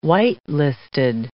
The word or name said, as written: white-listed